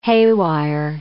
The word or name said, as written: haywire